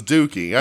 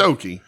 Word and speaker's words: Doki: Because everybody thought it was Dookie. I used to think it was Dookie. – And the crowd was chanting, Holy Dookie. – Holy Dookie. Yeah. Well, it's Doki. Because it rhymes with Choki.